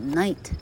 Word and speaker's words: knight: 大约在一千年前呢，有些人是称为骑士，Knight，K-N-I-G-H-T，它跟夜晚 night 就是同音异义字。所以 K N 开头的英文字的 K 大致上是没有发音的，直接略过那个 K 啊，所以念作 Night。 Knights were important men who were good。